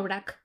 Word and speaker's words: Brač: Es verdad que pasamos por delante de Brač, que es la más famosa de todas las islas, porque tiene una lengua de arena. – Yo la llamo Brač. – Brač, se escribe Brač, claro.